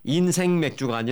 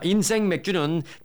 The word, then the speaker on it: 인생 맥주: 인생 맥주가 아니라 인생 맥주입니다. 제가 발음을 세게 한게 아니라 원래 이름이 그렇습니다. 자, 인생 맥주는 개점 첫날부터 부에나팍에서 방문객이 몰리며 대기 줄이 길게 늘어서고 있습니다.